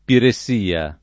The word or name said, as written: piressia